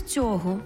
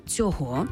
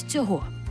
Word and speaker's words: цього: Саме з цього, а я наголосила «цього», починається справжнє відкриття для себе української, щоб поглянути на неї комплексно. А чи можна сказати, наголосивши так, саме з цього? Так, можна. Але в речення маємо додати ще одне слово.